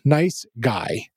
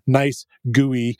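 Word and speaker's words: NiceGUI: I featured it as a library during my project section there, and I pronounced it nice GUI, kind of emphasizing the GUI of the graphical user interface of it. But supposedly, they would like it pronounced as guy.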